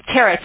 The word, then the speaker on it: Tara: My name again is Tara. – Is that, how do you pronounce that? – Tara, T-A-R-A.